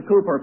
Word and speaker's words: Cowper: Or as William Cooper put it, by the way, when I was in Scotland, they went out of their way to tell me that the way you pronounce Cowper is Cooper, and so I've been doing it since then.